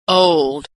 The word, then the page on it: old: American English